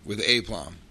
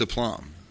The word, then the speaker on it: aplomb: And he, once I said aplomb, I was turning around, I was like, with aplomb. And he goes, you don't seriously think it's pronounced aplomb. And I was like, what? And he goes, it's aplomb. I said, it's aplomb? And he goes, yeah.